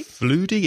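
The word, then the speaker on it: Fleudy: We have a comment on the website from, I think it's pronounced Floudy or Floody, would that be? – Floody, it's F-L-E-U-D-Y. Hello, Floody.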